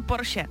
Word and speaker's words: Porsche: Všichni říkají Porsche špatně, ale je to opravdu Porsche, takže tady si můžeme jako poklepat na rameno, že to říkáme dobře.